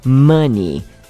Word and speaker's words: money: Não se pronuncia money, como os Mamonas Assassinas cantavam, tá bom? Nem many, porque aí já é outra palavra da língua inglesa. A pronúncia real é money.